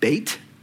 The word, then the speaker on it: Beit: So in Hebrew, Beth, or be probably better pronounced Beit.